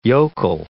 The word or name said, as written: yokel